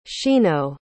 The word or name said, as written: shino